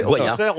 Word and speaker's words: Boyard: Alors, la première leçon de cette chronique, c'est qu'en la faisant, je me rends compte que je n'ai jamais bien prononcé Fort Boyard. J'ai toujours dit Fort Boyard. – Non, c'est Boyard. On devrait dire Boyard. – Ah, on dirait Boyard. J'avais raison. Boyard comme boyau.